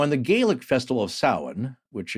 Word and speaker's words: Samhain: I'm working on getting him his own social security number, but it's spelled Samhain, but it's pronounced Samhain.